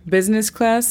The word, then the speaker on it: business class: La pronunciación fonética es algo larga, pero es K-E-A-N-S-E-L-E-I-S-H-O-N. – Cancellation. – Muy bien. La siguiente palabra es business class. Business class significa clase ejecutiva. Estamos hablando de niveles dentro del avión. Clase ejecutiva. La pronunciación fonética es B-I-S-N-E-S, business, y class es K-L-E-A-S.